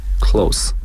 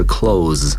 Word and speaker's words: close: Is that close 应该读成 close 而不是 close，因为 close 是动词， close 在这里是形容词。